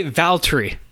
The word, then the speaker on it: Valtteri: They say Valtteri. It's almost like it's two syllables instead of Valtteri.